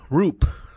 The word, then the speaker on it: Roupp: It's actually Roop. R-O-O-P is the way they pronounce it on baseball reference. So I've been calling him Raup because it looks like that. R-O-U-P-P, but it's Roop. R-O-O-P pronunciation.